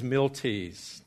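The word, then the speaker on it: Miltitz: It's not M-I-L-I-T, and I did hear a German pronunciation, and so let's go from what I did say, and I won't repeat that so you don't get confused. This is Miltie's. That's the way the Germans pronounce it.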